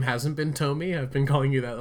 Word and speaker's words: Tomy: And I, said Tommy earlier, so you said Tommy, but he was a great ranger. So we'll no, that's fine, it's fine. I might be pronouncing it weird too, but yeah, Takara Tomy, I think. Let's go with Tomy.